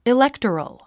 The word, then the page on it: electoral: iléktərəl; イレクトラル